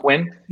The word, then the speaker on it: Nguyen: Is it Nguyen? I never get it right. – Luke Nguyen. – Nguyen. That was close. Yeah. – Yeah. – Nguyen.